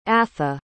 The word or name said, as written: atha